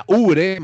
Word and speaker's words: Oubre: Suena Ubre, Marulanda, pero se escribe Obre, ¿no?